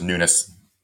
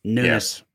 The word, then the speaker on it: Nunes: BJ Anderson from Facebook says, "You guys see where Representative Devin Nunes, or it's Nunes, right? I'm saying that right. Is he's a Portuguese nationality, I think. I think it's just Nunes. – Nunes.